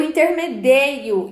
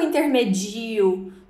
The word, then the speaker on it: intermedeio: Na primeira pessoa do presente indicativo já temos a irregularidade marcada. Eu intermedeio e não eu intermedio.